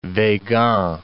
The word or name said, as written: Weygand